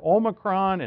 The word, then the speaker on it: Omicron: I hear Omicron and Omicron. It's Omicron, by the way. There's two O's in Greek. See, you get a lot of this now that I'm taking Greek. You're probably sick of it. I'm not trying to show off. It's God's the language that God chose for his Bible. But there's two O's. There's an Omicron and an Omega. So we generally pronounce the Omicron as Omicron.